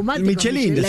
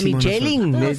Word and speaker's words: Michelin: Michelin. – La Michelin. – ¿Qué estamos hablando? – Es Michelin. Pero es Michelin, ella lo pronuncia bien en francés.